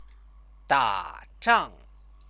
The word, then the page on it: dazhang: chinese